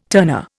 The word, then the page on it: turner: Indian